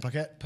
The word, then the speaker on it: Paquette: Did they say your last name Paquette? – Paquette, yeah.